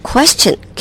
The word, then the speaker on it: question: question，没错， question， q u e s t i o n，因为它的拼法结尾是 s t i o n，所以要念成 question。